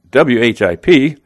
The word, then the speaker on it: WHIP: This Ohtani is punching guys out at a 10.2 per 9 clip, but leading the majors in ERA with 0.97 and WHIP, sometimes pronounced whip, at 0.81.